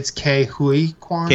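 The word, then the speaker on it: Ke Huy Quan: Ki Hai Quan? Is that how you say it? – Under the cast, it's Jonathan Ki Kwan. – Oh. – And I think it's Ke Hui Kwan.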